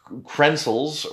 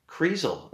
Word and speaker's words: Kreisel: There's no N. It's not pencils. It's not Dennis pencils or Dennis Krenzels or whatever I was saying. It's Kreisel.